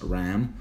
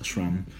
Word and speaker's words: SRAM: That's actually a lot of people here in Colombia pronounce it as RAM. – Yeah. But since this is a German word, it's pronounced SRAM.